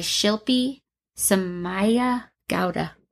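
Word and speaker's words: Shilpi Somaya Gowda: The first one on my list is Secret Daughter by Shilpi Samaya Gowda. I'm sure I totally butchered that. That was well pronounced.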